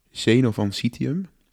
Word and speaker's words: Zeno van Citium: Die uh, Zeno van Citium, dat is de correcte uitspraak.